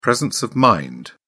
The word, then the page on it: presence of mind: British English